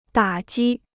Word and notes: 打击: dǎ jī

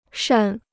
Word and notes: 善: shàn

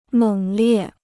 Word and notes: 猛烈: měng liè

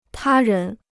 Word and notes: 他人: tā rén

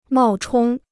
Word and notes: 冒充: mào chōng

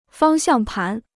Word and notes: 方向盘: fāng xiàng pán

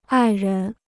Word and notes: 爱人: ài ren

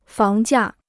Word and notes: 房价: fáng jià

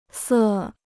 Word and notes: 色: sè

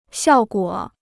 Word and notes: 效果: xiào guǒ

